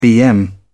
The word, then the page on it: bm: British English